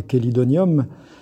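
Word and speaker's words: Chelidonium: Si on part de son nom latin, qui est Quélidonium, et on dit bien, alors ça s'écrit che, mais on dit bien que.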